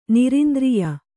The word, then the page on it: ನೈರಂತರ್ಯ: nairantarya